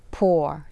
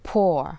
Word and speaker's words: pour: ขอให้สังเกตการออกเสียงคำว่า pour ที่หมายถึงเทนะคะหากเป็นการออกเสียงแบบอังกฤษนะคะคือ pour แต่หากเป็นการออกเสียงแบบอเมริกันนะคะเราจะออกเสียงว่า pour